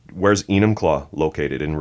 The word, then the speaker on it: Enumclaw: Enumclaw, it's pronounced.